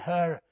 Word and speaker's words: Hur: Aaron and Hur. Hur sounds like you're talking about her.